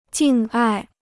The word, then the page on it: 敬爱: jìng ài